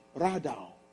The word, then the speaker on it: radaw: This word in the Hebrew language, Genesis 1:26, is the word R-A-H. Radaw. Sometimes it's spelled R-A-W-D-A-W, depending on the Hebrew translator. The word Radaw, R-A-D-A-W, do you know what it means?